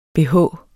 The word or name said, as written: bh